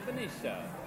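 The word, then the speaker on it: Venezia: Well, that's the Italian pronunciation. It'll be Venetia.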